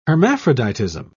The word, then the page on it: hermaphroditism: British English